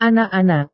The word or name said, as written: anak-anak